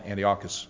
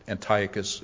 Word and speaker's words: Antiochus: In fact, in history, I call him Antiochus, and Antiochus is probably the correct pronunciation.